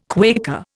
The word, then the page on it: Quaker: Indian